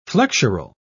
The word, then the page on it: flexural: British English